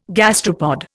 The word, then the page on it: gastropod: Indian